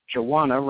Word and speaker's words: Jawana: Jawana rhymes with Tawana.